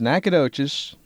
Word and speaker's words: Nacogdoches: Then there's Nacogdoches, pronounced with a silent G, and Nacatish, the city named after his mythical twin Cadoan brother, of course pronounced that way instead of Natchitoches.